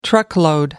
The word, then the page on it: truckload: American English